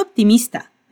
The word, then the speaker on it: optimista: Y dice que cree que la mujer está siendo demasiado optimista. Repite, demasiado optimista. Cuidado al pronunciar la P en optimista. Vas a cerrar los labios sin producir un sonido y luego viene el sonido de la T.